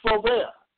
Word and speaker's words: Robert: It's spelled Pascal Robert, but it's Robert.